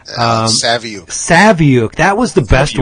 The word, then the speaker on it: Saviuk: because I've been calling him Savick forever. Yep. And he goes, there's a U in it. – Why would you say Savick? He said, is Saviuk rhymes with book?